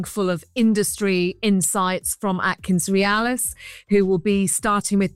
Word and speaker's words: Atkins Realis: Is that right, Atkins Realis? – That's perfect, Richard.